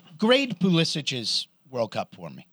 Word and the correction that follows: Pulisic: Pulisic. – Pulisic. – That's his Polish name. – That's how you say it.